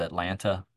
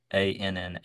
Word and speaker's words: Atlanta: Anybody says Atlanta, that's not the town I grew up in. It's Annah is probably the better pronunciation of that word.